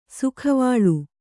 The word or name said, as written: ಸೌಗವಲ್ಲಿ